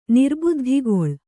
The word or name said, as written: ನಿರ್ಬುದ್ಧಿಗೊಳ್